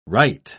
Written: rite

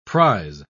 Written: prise

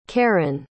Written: karon

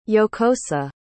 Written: jocosa

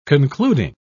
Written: concluding

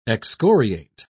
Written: excoriate

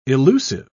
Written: illusive

